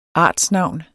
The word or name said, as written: artsnavn